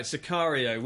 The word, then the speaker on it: Sicario: We talked about Crimson Peak, but the main film you were bursting at the seams to get out was Sicario. Am I saying that right? – That's right, Sicario, which I've been reliably informed by the trailer means hitman in Spanish.